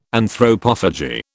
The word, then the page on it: Anthropophagy: British English